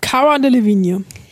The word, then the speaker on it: Cara Delevingne: Cara Delevingne.